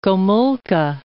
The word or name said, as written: Gomulka